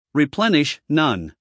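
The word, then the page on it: replenish: uk rɪˈplenɪʃ; en-us rɪˈplenɪʃ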